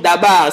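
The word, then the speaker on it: dabar (דבר): Le mot « chose » ici, là, je suis allé chercher, c'est un mot hébreu que l'on prononce « dabar ».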